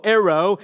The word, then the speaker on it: airo: the Greek word aero. If I was to spell that in English, it would be A-I-R-O, aero.